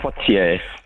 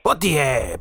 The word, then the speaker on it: Poitiers: How do you say it properly? – Poitiers. – Poitiers, Poitiers. Said absolutely beautifully.